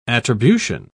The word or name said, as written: attribution